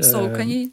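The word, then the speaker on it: Saucony: Тема произношения — это на самом деле отдельная тема, потому что если прям совсем правильно, то да, это Nike, это Adidas, это Э, Сокони.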